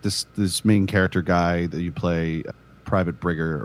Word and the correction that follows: Bridger: or whatever, he is Bridger. Sorry, I pronounced it wrong.